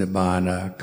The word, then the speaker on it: nibbana: In Pali, those words are pronounced nibbana, kama, and dhamma, and there's a lot of other examples of that.